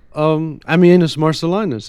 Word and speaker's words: Ammianus Marcellinus: Well, Ammianus Marcellinus is a very interesting figure.